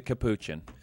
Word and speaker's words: capuchin: Since he's a capuchin.